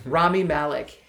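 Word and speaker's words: Rami Malek: So the gist of the game is the Ramin Malik character, is that uh-huh. how you say his name? – Rami not Ramen, not like Top Ramen. Rami Malik.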